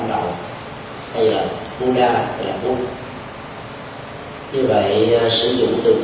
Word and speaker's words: Bút: dầu là phát âm theo một cái phương ngữ nào thì âm chuẩn là Bút. Người tổ tiên Việt Nam đã đọc là Bút.